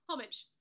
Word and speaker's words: homage: Homage. – Doesn't sound quite right, does it? But homage. Anyway, moving. – Homage. The H is silent.